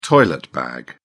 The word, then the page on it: toilet bag: British English